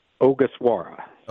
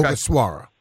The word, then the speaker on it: Ogasawara: I believe I have it finally. It's Shinosuke Ogasawara. – Okay. Ogasawara.